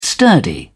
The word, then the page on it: sturdy: British English